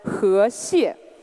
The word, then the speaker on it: 河蟹: So river crabs in Chinese is pronounced as 河蟹, which is again a near homophone of the word 和谐, which means harmony.